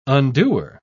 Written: undoer

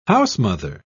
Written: housemother

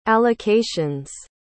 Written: allocations

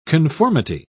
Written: conformity

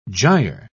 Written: gyre

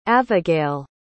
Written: avagail

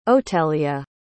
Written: otelia

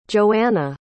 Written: yoana

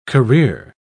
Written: career

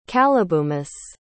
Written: calibumus

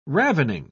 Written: ravening

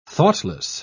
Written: thoughtless

